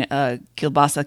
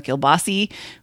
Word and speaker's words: kielbasa: emails for the day, we get one from Justina on the pronunciation kielbasa kielbasi, which she says is singular versus plural, which was a great and simple explanation, I thought.